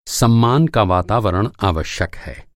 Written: वातावरण

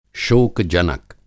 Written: शोक-जनक

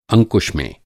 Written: अंकुश

